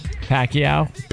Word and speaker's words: Pacquiao: That's, how are you supposed to say his name? – Pacquiao.